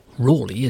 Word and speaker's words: Raleigh: Another of the accused, none other than Sir Walter Raleigh, or Raleigh as he would have pronounced it, knocked a big hole in the prosecution case when he revealed that Wade had been torturing witnesses against King James's explicit order.